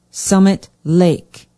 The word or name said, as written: summit lake